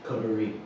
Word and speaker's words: coterie: What is, sorry if I mispronounce it, the party Cotier? – Coterie. – Coterie.